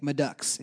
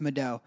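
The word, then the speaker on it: Madeaux: But every time someone would mispronounce it, they'd be like, Madux. And he's like, no, it's Madeau. And it's one of those things, like, when you hear that E-A-U-X is O, you never go back to not knowing it's Madeau.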